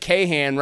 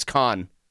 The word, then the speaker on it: Kahan: Like Kahan rhymes with Mayhan, but it's Khan.